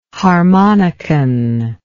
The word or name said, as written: harmonicon